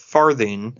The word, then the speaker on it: Farthing: Jack Farthing, not Farthing, like I was about to pronounce it, but Farthing, as in like a form of currency in England.